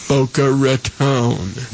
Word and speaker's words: Boca Raton: Not Raton, Raton. Boca Raton.